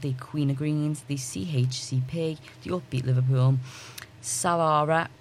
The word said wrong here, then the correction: Savara: am I saying that right? Savara UK, yeah,